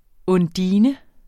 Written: undine

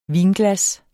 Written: vinglas

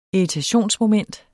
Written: irritationsmoment

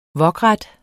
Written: wokret

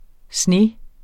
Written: SNE

